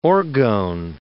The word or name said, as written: orgone